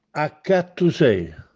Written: Accattusè

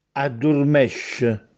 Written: Addurmèsce

